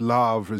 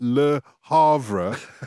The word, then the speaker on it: Le Havre: Lavre, as you pronounce it, the rest of us call it Le Havre.